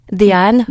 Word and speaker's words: Dehaene: Alors je ne suis pas sûre de bien prononcer le nom, c'est Stanislas Dehaene. C'est ça? – Dehaene, j'étais pas sûre de ma prononciation.